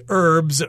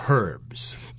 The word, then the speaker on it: herbs: I should note that in America, we don't pronounce the H. We say herbs, herbs and spices, but I believe in the UK, they tend to pronounce that H. They say herbs.